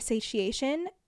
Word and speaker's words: satiation: The first is extinction, the second is satiation, which sounds I know I know that's how you say it cuz it's like satiate, but satiation is not a form of the word I've ever heard before.